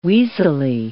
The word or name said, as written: weaselly